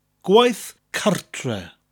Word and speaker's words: gwaith cartre: Gwaith, which has that slight G, that slight G sound at the beginning of the word, and a th, a TH sound at the end. Gwaith. Gwaith means work, followed by the word cartre. It almost sounds like two English words put together, cart and re, cartre.